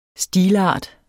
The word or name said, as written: stilart